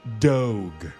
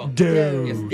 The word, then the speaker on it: Dogue: Well, it's actually pronounced like dog, but if a snooty French person said it would be Dogue.